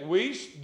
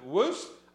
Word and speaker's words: Wuest: Vincent is spelled V-I-N-S-E-N-T. You can get Wiest, W-U-E-S-T. Some pronounce it Wiest, some pronounce it Woost. I don't really care how you pronounce it, but get it.